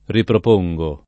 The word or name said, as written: riproporre